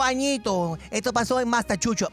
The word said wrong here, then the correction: Massachusetts: Massachusetts. – ¿Cómo se dice? Nunca, siempre he tenido problemas. Perdón, mis oyentes. Tengo hasta novios en Machuchu. – Massachusetts. – It's Massachusetts.